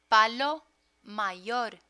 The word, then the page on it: palo mayor: Spanish